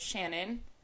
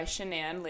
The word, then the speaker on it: Shanann: So Shanann, she originally pronounced it Shannon, but she started going by Shanann later in life.